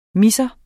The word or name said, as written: misser